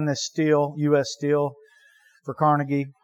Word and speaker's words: Carnegie: We always say his name wrong. It's actually Carnegie.